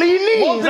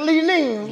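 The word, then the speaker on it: Li-Ning's: I like the fact that you said it right. – It's called Lee Ning's. – Most people say Lee Ning Ning Ning or something.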